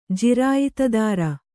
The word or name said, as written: ಜಿರಾಯಿತುದಾರ